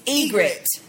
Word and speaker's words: Ygritte: It's Ygritte.